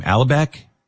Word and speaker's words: Alibek: But I want you to tell people the story about what Ken, I can't remember how to say his last name, Alibek, is it? – Yeah, exactly.